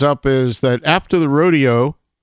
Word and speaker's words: Rodeo: If you go, first of all, to Rodeo, that's the way Copeland pronounced it in his musical composition, Rodeo.